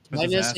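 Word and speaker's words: Timaeus: Timus and Critias. That's how you say it.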